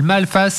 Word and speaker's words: Malphas: Ça s'appelle Malface. Ça s'écrit MALPHAS, et c'est le début d'un diptyque, c'est proposé par les éditions Ankama.